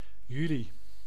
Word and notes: July: /dʒuˈlaɪ/

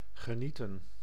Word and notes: genieten: [ɣe.niː.tǝː]

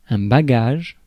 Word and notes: bagage: [ba.ɡaʒ]; /ba.ɡaːʒ/

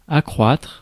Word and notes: accroître: /a.kʁwatʁ/

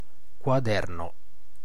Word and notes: gathering: American English

